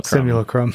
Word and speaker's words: simulacrum: I still can't pronounce that word. I always say solemn simulacrum. – Simulacrum. – Whatever, yeah.